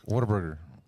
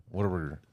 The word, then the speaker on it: Whataburger: Whataburger. Say it like that. Whataburger.